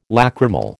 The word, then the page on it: lacrimal: American English